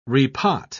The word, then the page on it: repot: British English